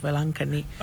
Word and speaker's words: Velankanni: In the same way, in India, we have a popular shrine called Our Lady of Velankanni.